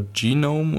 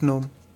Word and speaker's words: GNOME: Heißt das eigentlich Gnome oder Genome? Oder? – Gnome, glaube ich. Gnome. Ich habe das vorher auch schon mal falsch gesagt. Aber ich wurde da schon mal drauf hingewiesen. Das ist Gnome.